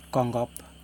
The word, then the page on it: koNgop: Yokoim